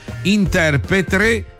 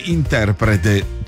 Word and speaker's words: interprete: Ciao, io non ho particolari difficoltà a pronunciare parole, però inorridisco quando in TV sento spesso pronunciare la parola interpretre invece che interprete.